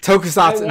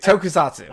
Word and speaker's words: tokusatsu: Tokatsu. How do you say that word? – Oh, the I can't pronounce Japanese words. – Yeah. – Tokusatsu. No, I'm just kidding. Tokusatsu.